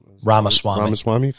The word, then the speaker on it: Ramaswamy: You got DeSantis, you have Tim Scott, you've got Vivek Ramaswamy, is that how you say his name? – Ramaswamy, thank you.